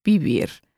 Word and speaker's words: vivir: In the last episode, we went through the conjugation of the verb vivir, and when I put that into practice, I was told that vivir was actually pronounced vivir with a B sound.